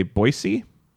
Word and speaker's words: Boise: People in Boise say Boise with more of an S sound.